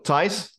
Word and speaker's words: Thijs: So Thijs, like Tyson without the O-N bit at the end.